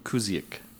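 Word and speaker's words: Kuzyk: Played by Mimi Kuzki. – Also very good. – Kuzik. I butchered that one.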